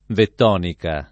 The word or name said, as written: vettonica